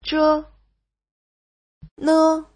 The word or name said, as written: zhe5ne5